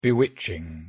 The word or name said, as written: bewitching